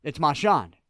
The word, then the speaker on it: Marchand: He came out and said, no, it's Marchand.